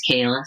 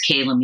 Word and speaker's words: Kayla: My name is Kayla, so it's Kayla Music. And you can always reach me at Kayla at Kayla Music.